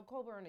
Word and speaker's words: Cockburn: y'all know Coburn is spelled C-O-C-K-B-U-R-N. Mm-hmm. And I didn't know that C-O-C-K-B-U-R-N was Coburn. – The C-K is silent.